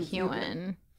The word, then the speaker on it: Heughan: Sam Heughan.